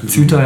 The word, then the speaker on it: Ceuta: oder so wie, so wie so die Afrikaner in, in Keuta, ähm, äh, oder wie man es richtig ausspricht, war bestimmt falsch ausgesprochen, also in diesem spanischen, in dieser spanischen Enklave in Nordafrika, Ceuta, ja, danke, mhm.